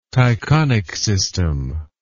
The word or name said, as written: Tychonic system